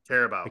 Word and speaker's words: Carabao: Is it the Carabao Cup? – Carabao.